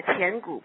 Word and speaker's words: Qian Gu: That's the Quin Gu. How do you pronounce that? – It's Qian Gu points.